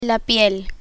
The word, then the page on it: piel: Spanish